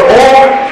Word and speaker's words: Om: The Om sound has three syllables. That is A, U and Ma.